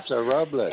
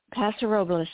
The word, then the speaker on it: Paso Robles: Paso Robles. – And, Paso Robles. You said it correctly. Paso Robles, yes.